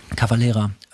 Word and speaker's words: Cavalera: Das erste Mal habe ich solche Gitarren, oder diese Gitarren, glaube ich, Max Caballera, wie spricht man den Namen aus? – Cavalera.